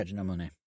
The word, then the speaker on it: Monáe: I forgot how to pronounce, Janelle Monáe? – Yeah, Janelle Monáe. Yep.